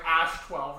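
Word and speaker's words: X Æ A-12: Yeah, but it's pronounced so like Ash, like Ash 12.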